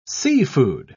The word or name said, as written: seafood